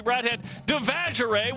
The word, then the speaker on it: Broadhead-Devashrayee: And West Coast Conference Player of the Year, Cassie Broadhead-Devashrayee, will join us.